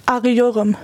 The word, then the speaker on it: Arıyorum: Wie spricht man das auf Türkisch richtig aus, das Wort? – Arıyorum.